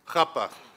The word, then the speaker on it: chuppah: You've heard the huppah. How do you say it? Chuppah. There we go.